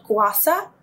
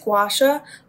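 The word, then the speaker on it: gua sha: The second part is called guasa. I pronounce it guasha, but they use like this little ceramic tool.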